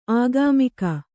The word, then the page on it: ಆಗಾಮಿಕ: Kannada